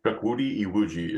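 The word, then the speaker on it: Chukwudi Iwuji: Chuck Woody Iwuji is the way I know to say it. – Chuck Woody Iwuji? – Chuck Wood, yeah. Chuck Woody Iwuji.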